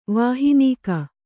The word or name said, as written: ವಾಹಿನೀಕ